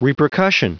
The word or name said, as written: repercussion